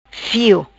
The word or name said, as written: Fio